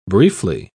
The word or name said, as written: briefly